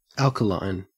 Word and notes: alkaline: Australian English

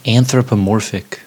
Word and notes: anthropomorphic: American English

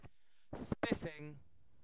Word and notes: spiffing: British English

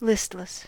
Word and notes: listless: American English

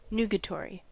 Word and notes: nugatory: American English

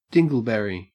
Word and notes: dingleberry: Australian English

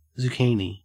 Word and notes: zucchini: Australian English

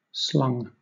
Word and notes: slung: British English

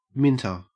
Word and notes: minter: Australian English

